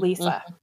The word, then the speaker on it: Lisa: Until I watched it today with subtitles on, I thought her name was Lisa with a Z, because that's how Jimmy Stewart pronounces it. – That's funny. – It's a hard S, folks. Mm-hmm. Lisa.